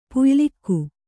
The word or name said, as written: ಪುಯ್ಲಿಕ್ಕು